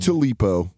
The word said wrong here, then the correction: Tupelo: How do you say that in Mississippi? – Tupelo, Mississippi.